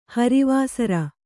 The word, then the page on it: ಹರಿವಾಸರ: Kannada